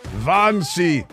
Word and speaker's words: Wannsee: I am being reminded by our wonderful, well-educated listeners that in Germany, Wannsee is pronounced with a V, Wannsee.